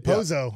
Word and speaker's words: Pozo: Pozo. Yeah. It's an easy one. – Because it's like bozo.